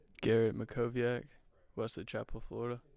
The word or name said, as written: Garrett Mackowiak